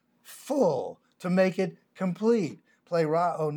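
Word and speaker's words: pleroo (πληρόω): We have the Greek word pleroo, P-L-E-R-O-O. The E is pronounced as an A, pleroo.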